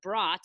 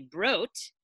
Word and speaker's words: Brot: And since we're going on pronunciations, I'll also correct that brot is actually broat. – Oh, broat. – Yeah. Well, so broat means bread in German.